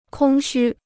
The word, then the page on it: 空虚: kōng xū